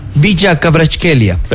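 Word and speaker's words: Khvicha Kvaratskhelia: Bicha Kvaratskhelia.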